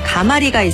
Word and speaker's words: 가마리: 발음이 까마리라고 해서 까마리로 표기하면 틀리는데요. 가마리가 발음 표기입니다.